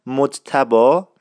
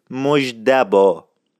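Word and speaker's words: مجتبی: اسم مجتبا معمولا توسط افراد نزدیک، دوستان، آشنایان و خانواده و اقوام وابسته به صورت مجدبا تلفظ میشد. تکرار میکنم، مجتبا میشد مجدبا، یعنی اون ت به دال تبدیل میشد که این خودش یه مبحثی بسیار پیچیده است و من اینا در جلسات بعدی قشنگ میشکافم.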